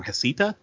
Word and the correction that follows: Hestia: Is that how you say it? – Oh, the Hestia.